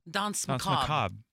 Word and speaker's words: Danse Macabre: Danse Macabre. – I don't know why. – Some people don't even pronounce the R on the end. So you just say Danse Macabre. – Danse Macabre.